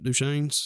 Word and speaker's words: Duchenne's: And I think I said that right, Duchenne's. – Mm-hmm. You're right.